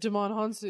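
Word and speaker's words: Djimon Hounsou: Damon Hanzu? – Yes. – I can never say his name right. – Damon Hanzu.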